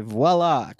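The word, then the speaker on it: Voilà: Song number 10, Imaginary Friends by Voila, or I don't know if I'm saying this right. It's spelled V-O-I-L-A. – Yeah. – Voila. – Yeah. – But the A has a little accent mark at the top.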